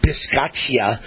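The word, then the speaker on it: Bisaccia: First of all, the Raiders coach, I can never pronounce his last name, but my understanding is it's pronounced Rich Biscaccia.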